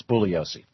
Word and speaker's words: Bugliosi: And by the way, he doesn't pronounce the G in his name. It's Bugliosi.